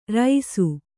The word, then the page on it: ರೈಸು: raisu